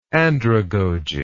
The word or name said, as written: andragogy